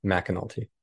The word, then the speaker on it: McAnulty: It's Bryan McAnulty. Okay. So it's spelled M-C-A, but it's pronounced Mac, McAnulty.